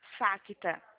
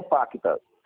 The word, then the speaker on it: Facta: Facta. É com F. – Facta. – Isso. Banco Facta. – É Facta ou Pacta? – Ó, Facta. Banco Facta.